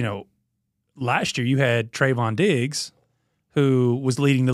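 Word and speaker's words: Trevon: Well, I mean, you come off of the last year with Tavon Diggs, right? I mean, did I say that right? Is it Trayvon or Tavon? – I think it's Trayvon. – Trayvon, yeah, so my bad.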